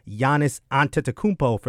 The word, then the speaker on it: Giannis Antetokounmpo: Giannis Antetokounmpo, for those that don't know how to pronounce it correctly, of the Milwaukee Bucks, rounds out the starting five.